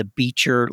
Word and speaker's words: Beecher: but the Beecher Lecture Series.